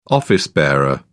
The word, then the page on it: office-bearer: British English